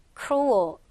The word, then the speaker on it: cruel: 발음은 cruel. 약간 어에 가깝게 발음해주면 됩니다.